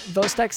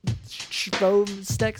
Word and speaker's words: Chvostek: The signs of neural hyperexcitability in hypocalcemia include the Vostek sign, and that's spelled like C-H-V-O-S-T-E-K sign. That's Vostek sign, but it's Vostek sign.